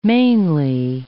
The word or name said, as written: mainly